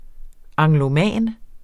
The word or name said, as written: angloman